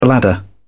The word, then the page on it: bladder: British English